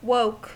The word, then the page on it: woke: American English